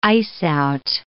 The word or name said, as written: ice-out